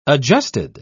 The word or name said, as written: adjusted